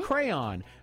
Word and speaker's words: crayon: According to Merriam-Webster, the proper pronunciation is 2 syllables. – Mm-hmm. – Crayon.